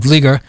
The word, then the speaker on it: Vlieger: For those just tuning into the show, our guest is Howard Vlieger. I'll spell that V-L-I-E-G-E-R.